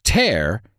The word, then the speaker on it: tear: Tear es una palabra con dos significados. El primero es romper o rascar, y en este caso se pronuncia como aire, pero con una T delante. Air, tear. El otro es lágrima, y su pronunciación es tear.